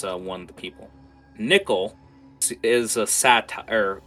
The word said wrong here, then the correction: satyr: Uh, how the hell do you pronounce that? Uh, I want to say satire so bad. Uh, a satyr. – Satyr. – A satyr. Thank you.